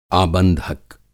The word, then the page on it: आबंधक: Hindi